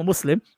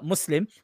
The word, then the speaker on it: Muslim: I'm a Muslim. That's the correct pronunciation, right? Now that's a Muslim.